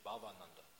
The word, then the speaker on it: Bhavananda: Bhavananda. So there's Bhavananda and Bhavananda. Bhavananda means one who is taking ananda from bhav, from emotions towards Krishna. And then bhav, when it's a short a, bhavananda, that means, because the devotee is saying, Prabhupada, what does that name mean?